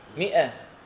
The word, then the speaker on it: مئة: مئة فيها زائد ولا ما فيها؟ ألف، هل ننطق الألف؟ ما ننطق.